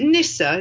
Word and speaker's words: Nyssa: Is it Nyssa? Is that how you say it? – Yes, it is.